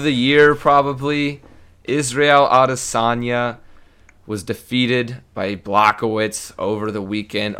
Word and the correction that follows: Błachowicz: Or, no, I'm saying that wrong. It's Błachowicz. Błachowicz, the Polish name.